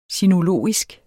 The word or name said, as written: sinologisk